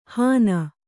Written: ಹಾನ

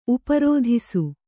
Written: ಉಪರೋಧಿಸು

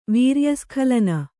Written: ವೀರ್ಯಸ್ಖಲನ

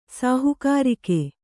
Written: ಸಾಹುಕಾರಿಕೆ

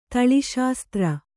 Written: ತಳಿಶಾಸ್ತ್ರ

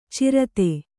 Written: ಛುರಿತ

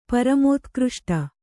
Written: ಪರಮೋತ್ಕøಷ್ಟ

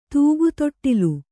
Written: ತೂಗುತೊಟ್ಟಿಲು

